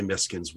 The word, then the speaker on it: Meiksins: It wasn't to do this episode, but I was reading Ellen, is it Miskin's Wood? I don't know how to say it. – Miskin's, I usually say Miskin's Wood, yeah.